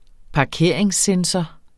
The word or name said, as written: parkeringssensor